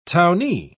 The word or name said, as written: townee